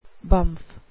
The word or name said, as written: bumf